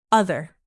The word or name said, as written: other